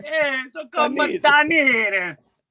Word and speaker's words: Dunedin: Although I can see how you could say Dundon, Florida. So how do you say it? – Dunedin.